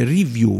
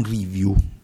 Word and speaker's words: review: Si dice review, non review, sia dal punto di vista del verbo to review o anche come sostantivo review, non l'accento sulla I.